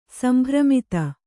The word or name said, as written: ಸಂಭ್ರಮಿತೆ